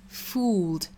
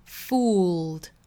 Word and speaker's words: fooled: 我们首先要注意的一个词呢，就是 fooled 这个词，就是 F-O-O-L 加 E-D。那它的读法呢是 fooled。这个 fool 这个词后面一加的发音就会很容易和另外一个词混淆，就是 F-O-O-D 这个词呢，我们知道读 food food，那前面的这个呢是 fooled fooled，区别呢就在于中间是有一个舌侧音的， F-O-O-L 读 fool fool，后面加的读 fooled fooled fooled，一定要注意里面这个舌侧音是不能丢的，舌尖去抵上齿龈，用喉咙发声。